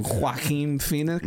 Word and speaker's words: Joaquin Phoenix: Joaquin Phoenix. Yeah, is that how you pronounce his name? – Yeah, man.